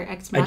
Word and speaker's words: Ex Machina: Is it Ex Machina or Ex Machina? – I just Googled it, and the correct pronunciation is, I think, Ex Machina.